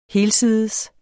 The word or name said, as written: helsides